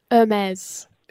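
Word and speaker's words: Hermès: Hermes. – It's Hermes.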